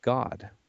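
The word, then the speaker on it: Gad: whose name is pronounced God.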